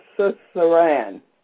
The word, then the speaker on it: suzerain: A covenant between a strong person or party and a weaker party is known as a suzerain. It's spelled S-U-Z-E-R-A-I-N, suzerain.